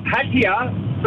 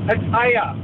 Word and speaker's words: Pattaya: Aussies call it Pattaya, but it's actually pronounced Pattaya. – Right, so we've ossified it to Pattaya, but it's pronounced Pattaya. – That's exactly right. That's how the Thai say it, Pattaya.